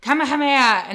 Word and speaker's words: Kamehameha: They would go Kamehameha and they'd shoot off their lightning bolts. So that's how she told me how to pronounce it, because it is a tongue twister.